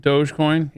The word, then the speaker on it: Dogecoin: Dogecoin? – Yeah.